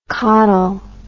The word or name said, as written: cotyl